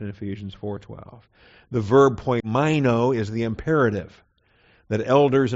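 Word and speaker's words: poimaino (ποιμαίνω): Versus poimaino, P O I M A I N O, poimaino.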